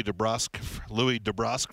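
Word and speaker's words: DeBrusk: And by the way, the family, Jake's grandfather has requested to go with the pronunciation DeBrusque. I've called Louis DeBrusque for 25 years.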